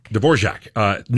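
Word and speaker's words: Dvořák: Dvorak, New World.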